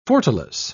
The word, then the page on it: fortalice: British English